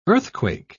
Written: earthquake

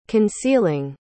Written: concealing

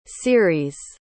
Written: ceres

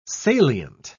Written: salient

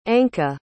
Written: Anka

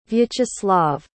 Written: Vyacheslav

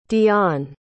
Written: dion

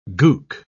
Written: gook

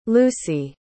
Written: lucie

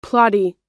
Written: plotty

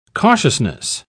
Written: cautiousness

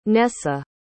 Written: Nessa

